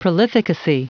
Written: prolificacy

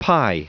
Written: pi

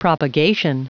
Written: propagation

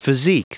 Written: physique

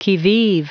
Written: qui vive

